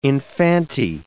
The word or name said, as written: infante